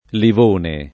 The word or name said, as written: livone